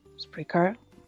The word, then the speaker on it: Spreaker: Spreaker. Se dice así, Spreaker.